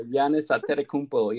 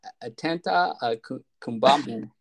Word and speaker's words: Giannis Antetokounmpo: Yeah, Giannis Antetokounmpo. Yeah, he would have been like Atenta Kumbamu.